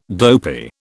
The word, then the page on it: dopey: British English